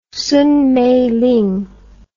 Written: Song Meiling

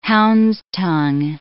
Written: hound's-tongue

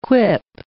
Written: quip

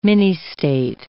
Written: ministate

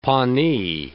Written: Pawnee

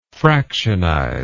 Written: fractionization

